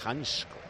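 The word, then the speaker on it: Hancko: Si a mí me dice Janko una vez, yo ya... – Que no, ya está. – Tú dilo como haya que decirlo, es que yo no sabía que se pronunciaba Hansko.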